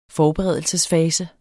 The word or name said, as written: forberedelsesfase